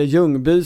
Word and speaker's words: Ljungby: Det finns en underbar Dieter Strand-text från Ljungby, eller Ljungby som du vill säga, eh, tror jag. Eh, det skånska uttalet av den här småländska orten.